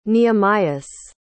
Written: nehemias

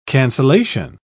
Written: cancellation

